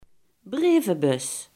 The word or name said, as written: brievenbus